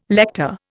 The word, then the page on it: lector: Indian